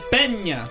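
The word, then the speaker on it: Pena: I believe it is pronounced the Pina National Palace, or maybe it's Pina, but I think it's Pina. – Palacio Nacional de Peña. – De Peña. Okay.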